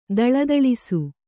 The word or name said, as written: ಥಳಥಳಿಸು